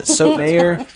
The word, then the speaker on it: Sotomayor: Sonia Sotomayor? Yeah, Sotomayor.